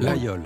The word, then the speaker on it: Laguiole: Oui, parce qu'il est né dans l'Aveyron, ce couteau à Laguiole, exactement. Alors ouais. Laguiole, c'est un petit village de montagne. – Laguiole ou Laguiole ? – Laguiole.